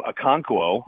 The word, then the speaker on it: Okonkwo: I think Chigakwankwo, I think I just butchered the name, Okonkwo.